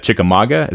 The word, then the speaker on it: Chickamauga: So you guys talk about also in the beginning of the book, your two hometowns of Salina and Chickamauga. Is that how you say it? – That's it.